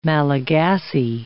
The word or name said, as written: Malagasy